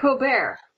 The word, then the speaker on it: Colbert: It's like Colbert. You don't pronounce the T like Voldemort.